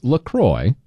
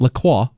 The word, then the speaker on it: LaCroix: Officially, it is pronounced LaCroix. It rhymes with enjoy, not LaCroix or LaCroix, like the French might say it.